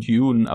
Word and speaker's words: Dune: Ich will das immer im Englischen mit so einem DJ aussprechen, so ein Dune, aber es stimmt ja gar nicht. Dune. Ich glaube, der heißt einfach nur, Dune. der heißt einfach nur Dune, wenn Dune. ich mich nicht irre.